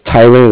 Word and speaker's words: Tyler: And my name is Tyler. That's spelled T-Y-L-E-R. And I'm calling with Cardiff. It's spelled C-A-R-D-I-F-F.